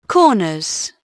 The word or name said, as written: corners